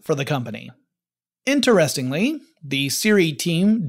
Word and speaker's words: Siri: The result would be a new company called Siri, S-I-R-I, which is kind of the way you would say SRI if you were trying to pronounce it as if it were an acronym as opposed to an initialism.